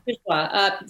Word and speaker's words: Bourgeois: Bourgeois, French artist, but she did a lot of sculpture.